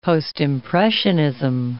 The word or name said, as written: Postimpressionism